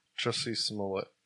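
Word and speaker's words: Jussie Smollett: It's Jesse Smollett. – It's a U. – Nah. – Jesse Smollett. – Like mullet with an S in front of it. Yeah. Smollett. – Jesse Smollett.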